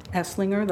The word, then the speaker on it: Esslinger: It's Chris Esslinger. The last name is spelled E, S as in Sam, L-I-N-G-E-R.